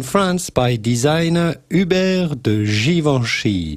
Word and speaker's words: Givenchy: Apparently, fellas, we pronounced, or I, and specifically, pronounced that French fashion designer wrong. You know, with the three-toed sandals. – It's not Givenchy. – It's a Versace. – Givenchy code. – Givenchy. Somebody goes, you are not saying that right.